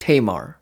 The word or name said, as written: Tamar, Hong Kong